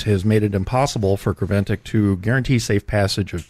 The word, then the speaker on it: Creventic: according to a statement released Tuesday by organizer Creventic. Uh, I believe that's how that's pronounced. – Looks pretty good to me. C R E V E N T I C.